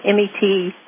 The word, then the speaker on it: metoprolol: One is clonidine, one is hydrochlorate, one is metrolol. I can't pronounce it. Metoprolol. – It's called metoprolol.